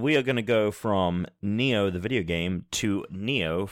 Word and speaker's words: Nioh: Oh, it's called Neo. Yep. It's spelled N I O H.